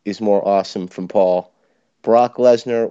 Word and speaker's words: Brock Lesnar: Depending on what we can arrange, if you New York people are not interested in seeing Brock Lesnar in action, perhaps our next WrestleMania will be razzing you from Philadelphia. – But before we go into that, you said it wrong. It's Brock Lesnar.